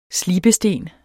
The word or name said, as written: slibesten